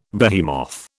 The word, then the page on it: behemoth: British English